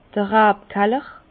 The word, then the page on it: d'r Rabkaller: Bas-Rhin; Herrlisheim